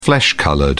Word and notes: flesh-coloured: British English

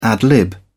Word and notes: ad-lib: British English

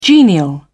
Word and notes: genial: British English

